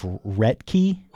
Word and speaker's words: Roethke: last name is spelled Roethke. And I believe it's something along the lines of Retke.